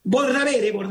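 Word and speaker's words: Bordabehere: Fue un culata excomisario que mató a Enzo Bondaere, no sé si lo digo bien, que fue... – Bordavere, que fue...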